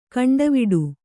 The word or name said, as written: ಕೊಂಡವಾಡೆ